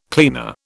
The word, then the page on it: cleaner: British English